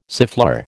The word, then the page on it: siffleur: American English